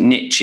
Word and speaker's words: niche: I know in a lot of, although you pronounce it niche in the States, but we say niche over here.